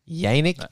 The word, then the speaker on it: Yannick: We're from Kentucky, so we mispronounce everything by default, but Unique out of Y-A-N-N-I-C-K. I don't know, man. – Yannick? – How's that not just Yannick? come on.